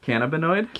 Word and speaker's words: cannabinoid: which is another important cannabinoid. How do you say that word? – Cannabinoid? – Cannabinoid.